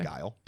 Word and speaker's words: Gial: I don't know if it's pronounced Gael. G-I-A-L. – G-I-A-L. – I think okay. – Gael. Right. Gael.